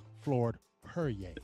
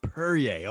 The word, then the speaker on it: Perrier: Floyd Perrier. – Perrier, okay. He said it right. It sounds like It sounds like Perrier.